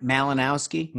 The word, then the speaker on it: Malinowski: Malinowski? – Mm-hmm.